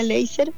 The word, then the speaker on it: Laser: Lo que pasa que la marca Láser, así se escribe Láser y se decía Laser, uh-huh.